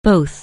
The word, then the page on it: both: anglais